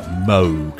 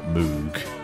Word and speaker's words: Moog: And we get a bit of, I've got to say it right, Moog, not Moog, on this as well. – Oh, yeah, no, I'm a Moog man.